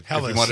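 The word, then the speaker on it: Helles: Hellas, if you really wanted to, hit it with the German pronunciation.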